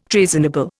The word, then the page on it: treasonable: Indian